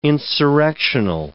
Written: insurrectional

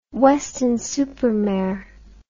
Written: Weston-super-Mare

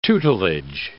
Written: tutelage

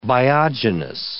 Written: biogenous

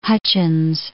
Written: Hutchins

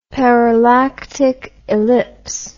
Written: parallactic ellipse

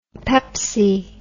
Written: pepsi

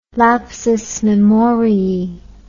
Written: lapsus memoriae

